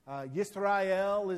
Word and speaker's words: Yisrael: Yisrael is the Hebrew pronunciation for that.